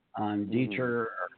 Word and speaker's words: Deicher: I had to actually ask Dan the proper way of pronouncing his last name too, because, you know, D-E-I-C-H-E-R, Deicher, or Deicher, and then he put it up as Deicher, and ever since then, I've been pronouncing Dan's name correctly, so that's awesome.